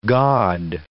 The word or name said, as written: gaud